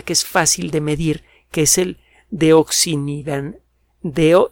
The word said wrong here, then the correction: desoxinivalenol: desoxinivalenol. – Ahora sí se lo dije bien.